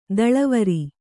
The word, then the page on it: ದಳವರಿ: daḷavari; Kannada